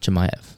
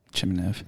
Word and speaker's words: Chimaev: What's his last name? Correctly. – Chimaev. – Thank you. – Chimaev. – That's right.